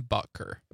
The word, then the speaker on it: Butker: Harrison Butker.